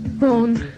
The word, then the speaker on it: Baughan: Born. – Born, spelled to B-A-U-G-H-A-N. Born. You get people all, you know, sort of Borgen and Bayon and all sorts of.